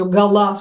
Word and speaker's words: Гала: 130 лет назад в этот день родилась Елена Гомберг-Дьяконова, ставшая потом женой Поля Элюара, который, кстати, и называл ее Гала с ударением на второй слог, а она-то себя называла Галиной.